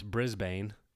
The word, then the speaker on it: Brisbane: We get to Brisbane, which it's spelt Brisbane, but they call it Brisbane. – Kind of like B-R-I-S-B-A-N-E? – Yep, exactly.